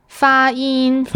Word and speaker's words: 发音: Pronunciation is 发音. Are my tones right on that? – 发音. – 发音 says two first. Okay, 发音.